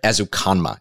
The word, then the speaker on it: Ezukanma: In the fifth round, I was able to grab Texas Tech wide receiver Eric Ezukanma is the way to pronounce his name.